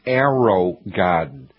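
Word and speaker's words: AeroGarden: There's something called an arrow garden, and it's spelled A-E-R-O garden, G-A-R-D-E-N.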